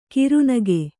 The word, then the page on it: ಕಿರುನಗೆ: kirunage